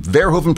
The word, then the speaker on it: Verhoeven: Somebody wrote and said, you're mangling Paul Verhoeven's name. Verhoeven pronounces his name Verhoeven.